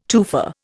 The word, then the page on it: tufa: Indian